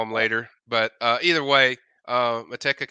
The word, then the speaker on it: Manteca: Manteca.